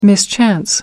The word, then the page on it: mischance: American English